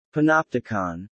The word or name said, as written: panopticon